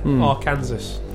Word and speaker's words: Arkansas: It's spelled weird, isn't it? – Yeah, because it's spelled like Kansas. – Yeah, it is. Yeah, Arkansas.